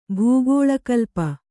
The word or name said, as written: ಭೂಗೋಳಕಲ್ಪ